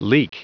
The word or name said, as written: leak